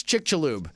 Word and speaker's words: Chicxulub: Well, you're not far off. It's Chicxulube. – Chicxulube. Oh, okay. Lube.